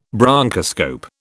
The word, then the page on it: bronchoscope: British English